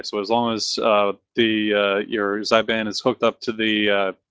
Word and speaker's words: Xbian: How do you say that? – I think it's pronounced Xbian. – Yeah, Xbian.